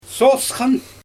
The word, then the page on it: sosxan: Xyzyl